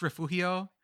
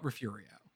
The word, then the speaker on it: Refugio: If you're from South Texas, in Spanish it's refugio, but if you the locals will call it refurio. – Mm-hmm. – So we're going to go with that. – Because G is pronounced like R.